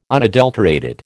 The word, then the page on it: unadulterated: American English